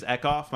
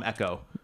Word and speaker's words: Ekoff: I had a friend, his last name was Ekoff. My mom would always call him Echo. Like, it's not the name. – Yeah. – Like, just say it right.